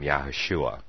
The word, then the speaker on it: Yahushua: Well, that's why I say his name Yahushua. And I'm fairly in like 99.9% sure that that is phonetically correct, though I may not have the accent, the true Hebrew accent down. But in English, that would be a phonetically correct pronunciation of the name of the Messiah.